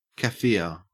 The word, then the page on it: kaffir: Australian English